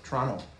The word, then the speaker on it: Toronto: Toronto. – Toronto. Canadians don't pronounce the T.